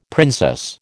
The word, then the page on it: princess: American English